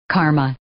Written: karma

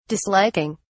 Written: disliking

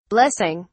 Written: blessing

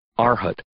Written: arhat

